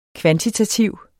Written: kvantitativ

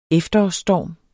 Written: efterårsstorm

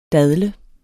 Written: dadle

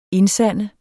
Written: indsande

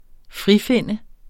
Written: frifinde

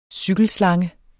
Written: cykelslange

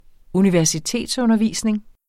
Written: universitetsundervisning

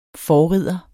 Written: forrider

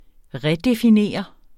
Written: redefinere

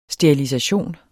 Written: sterilisation

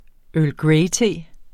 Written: earl

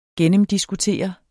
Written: gennemdiskutere

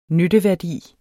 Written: nytteværdi

